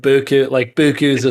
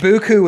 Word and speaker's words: beaucoup: I thought people just said beaucoup's a thing. So it's expression. – I thought beaucoup was spelled like this.